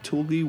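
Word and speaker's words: Tulgey: Finally, the Cheshire Cat figures received new special effects, and new figures of Alice were added to the Tulgi Wood and Mad Tea Party scenes. Did I say that right? – Tulgi Wood and Mad Tea Party scenes.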